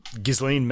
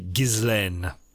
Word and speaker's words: Ghislaine: By the way, that Ghislaine Maxwell trial, her name is pronounced Ghislaine. It's a French name. I've heard people on the internet mispronouncing it, wondering how it's pronounced. It's a pretty standard French name. And it would be Ghislaine.